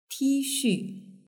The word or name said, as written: tixu